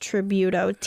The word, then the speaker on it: Tributo: It's the Ferrari F8 Tributo. I believe that's how you pronounce it. You'll know it when you see it. Here's a picture. – Oh, yeah. That's like the... – I believe it's pronounced Tributo. T-R-I-B-U-T-O.